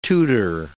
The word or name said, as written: Tudor